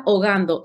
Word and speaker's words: Ogando: It's because it's a Spanish name. So my name is Angelica Ogando.